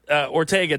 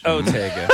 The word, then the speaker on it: Ortega: And again, it's not Ortega Tacos, as Phil called it earlier. – I did spell it Ortega because it's Otega.